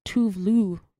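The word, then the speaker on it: Tove Lo: So I actually have had Tovlo on repeat a little bit. – Someone told me that it's pronounced Tuvlu. Because she's Swedish, right? – Tuvlu.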